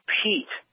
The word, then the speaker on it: Pete: You got the name wrong. It's Pete. P-E-T.